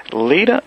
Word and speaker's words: Leeta: and him and Lita, that's your name? That's your wife's name, right? Am I saying that correctly? – L-E-E-T-A, right.